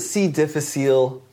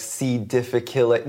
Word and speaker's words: C. difficile: But at least with treatment of, C. difficile? Am I pronouncing that right? – Yeah, everyone calls it C. difficile. Really, if you're a Latin nerd, it's like C. difficile. Nobody calls it that.